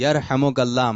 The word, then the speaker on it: یرحمک اللہ: کیا آپ کیا ہے, صحیح تلفظ کیا ہے؟ – یرحمک اللہ, یرحمک اللہ, ماشاء اللہ تلفظ درست ہو گیا آپ کا.